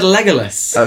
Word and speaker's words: Legolas: I said Legolas. – Okay. Yeah, it's a short O.